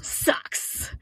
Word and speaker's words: Saw X: Is it pronounced Saw X, Saw 10, or Socks? – Socks.